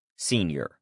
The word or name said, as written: senior